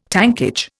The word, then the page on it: tankage: Indian